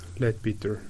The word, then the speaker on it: Leadbeater: kuidas seda nüüd hääldada, Leadbetter või? Leadbeater. – Leadbeater. Leadbeater võib-olla jah,